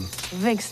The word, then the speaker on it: Vink: mispronouncing Vink. Let's hear it again. – Vink's the name. Dr. Vink. – With a V-V-V-V.